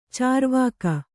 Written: ಚಾರ್ವಾಕ

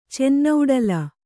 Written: ಚೆನ್ನೌಡಲ

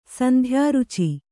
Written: ಸಂಧ್ಯಾರುಚಿ

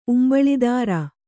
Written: ಉಂಬಳಿದಾರ

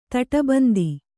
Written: ದಾಡಾಬಂಧ

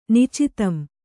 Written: ನಿಚಿತಂ

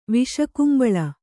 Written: ವಿಷಕುಂಬಳ

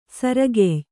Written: ಸೊರೆಗಾಯ್

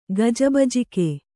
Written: ಗಜಬಜಿಕೆ

